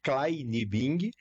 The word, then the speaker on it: Kleinibing: O meu sobrenome escreve Kleinibing, mas pronuncia Kleinibing, porque seria a junção de duas palavras em alemão. Klei, que é pequeno em alemão, e o Bing, que não tem tradução, mas seriam duas famílias, os Klei e os Bing, né?